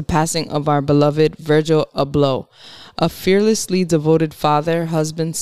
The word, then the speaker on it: Abloh: yesterday, Virgil, is it Abloh? – Abloh.